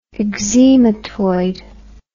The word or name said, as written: eczematoid